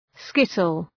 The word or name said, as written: skittle